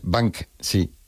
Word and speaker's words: Banksy: Pero bueno, como Bansky. – Esas palabras. Es Banksy.